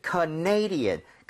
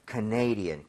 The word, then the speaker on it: Canadian: 왜냐면 캐나다는 Canada 이렇게 가는데 네. – 이게 캐나다 사람 이러면은 Canadian 강세가 뒤로 하나 밀려요. – 아. – 그래서 살짝 헷갈릴 수 있습니다. – Yes. – So Canadian, Canadian.